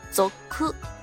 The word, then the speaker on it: 族: 它的发音呢，就是走科。